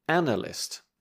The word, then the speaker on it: analyst: Then you mean an analyst? – Yeah. So you're back to the stress on the first syllable. Analyst.